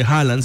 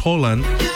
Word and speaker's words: Haaland: Y como dato curioso, no se dice Haaland, se dice Holland, porque la doble A en noruego se pronuncia como una O.